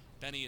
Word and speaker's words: Bene: Benny is spelled B-E-N-E. It's pronounced like the first name.